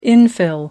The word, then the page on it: infill: American English